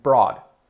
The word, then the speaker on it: brought: Bring en pasado, fijaos, se escribe brought. ¿Cómo lo pronunciarías? Sería brought. Lo traje ayer. I brought it yesterday. I brought it yesterday. Bring, brought, brought. Es sonido, ¿vale?